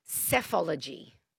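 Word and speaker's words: psephology: PSE. Apology. – Yeah, okay, good. Good, good, good. That's the one. It's and it's pronounced cephology.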